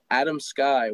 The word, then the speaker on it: Adamski: If you follow me on Instagram, it's at the Nate Adamski. That's THE. And then my name, Nate. And then my last name is spelled Adam Sky.